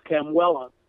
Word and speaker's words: Kamuela: Did I pronounce that right? – Yes, Big Island, the island of Hawaii. Okay. The town is actually Waimea, but for postal services, it's Kamuela, because there are three Waimeas in the Hawaiian chain.